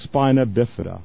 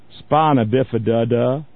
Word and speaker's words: spina bifida: And the man is so ignorant, he pronounced, he was trying to pronounce the name of a disease that could be cured. And it's clearly spelled, it's called spina bifida. Now, the way Bush pronounced it was spina bifida da.